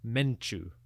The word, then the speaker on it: Montu: Although we call him Montu today, the god's name was probably pronounced Menchu or Montu in the ancient language.